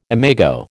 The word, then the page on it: imago: American English